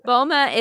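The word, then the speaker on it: Balmain: And we actually had a really great conversation with Olivier Rostang, who is the creative director of Belmain. Was that pronunciation good? – Perfect. Belmain is an old school heritage French brand started by Pierre Belmain right after the war.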